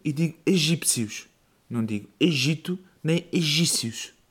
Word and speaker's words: Egito: Mudaram o nome do país de Egito, Egipto para Egito, porque supostamente o P é mudo. Mas depois é o Egito e os egípcios.